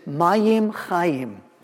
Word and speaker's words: mayim chayim: Living water in Hebrew is mayim chayim. It rhymes. And so there's this idea of God is going to restore the land.